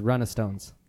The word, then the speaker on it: runestones: See? Look, ruin stones. – No, it's runestones.